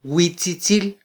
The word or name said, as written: witzitzil